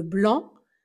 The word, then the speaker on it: blanc: Attention de ne pas prononcer le C à la fin. Blanc.